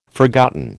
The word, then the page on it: forgotten: American English